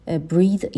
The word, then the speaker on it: breathe: Breathe یک فعل هست, آخرش ای داره به معنای نفس کشیدن. تلفظ به این شکل هستش، breathe، و breath یک اسم هست که آخرش ای نداره، تلفظش هم به این شکل breath به معنای نفس.